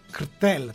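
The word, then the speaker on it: Škrtel: Αλλά έγινε viral η μετοπική του Σκρέτελ, του Σκρτέλ τέλος πάντων, όπως πρέπει να το προφέρουμε σωστά, με τον Diego Costa, τον Ισπανο-Βραζιλιάνο, ο οποίος Diego Costa τσακωνόταν και με τα ρούχα του καμιά φορά.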